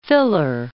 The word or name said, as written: filler